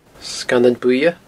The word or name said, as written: Sgannan Buidhe